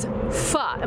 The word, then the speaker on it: pho: and your specialty is pho.